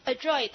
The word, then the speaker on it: adroit: 有技巧的、熟练的，还有个单词叫 adroit，这单词也叫做熟练的、有技巧的、娴熟的 adroit 哈，adroit 叫有技巧的、娴熟的。你看它的发音 adroit，好，我要抓它，是不是 adroit？